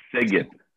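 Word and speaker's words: Szeged: It's spelled S-Z-E-G-E-D. I don't know how to pronounce it. – Siget.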